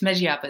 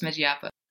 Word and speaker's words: Megiapa: But yeah, I mean, today I'm like, oh, this is how you say it. It's Megiapa. It's Megiapa.